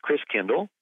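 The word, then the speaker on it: Christkindl: And the German pronunciation of Christ child is Chris Kindle. Chris meaning Christ, Kindle like kinder care, kindergarten means child. So Chris Kindle ends up being pronounced Chris Kringle. So Chris Kringle is really Chris Kindle, which means Christ child.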